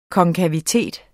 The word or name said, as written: konkavitet